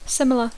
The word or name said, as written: similar